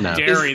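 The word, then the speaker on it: Derry: A place called Dairy that's not even spelled like Dairy.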